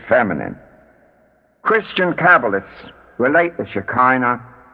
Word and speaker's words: Shekinah: One of the butchered pronunciations is Shekinah. Its real pronunciation is Shekinah. The K is guttural.